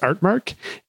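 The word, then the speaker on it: RTMark: like RT, RT, like the registered trademark symbol mark, but it's pronounced Artmark.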